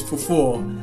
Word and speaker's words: fufu: yeah, the correct pronunciation is fufu.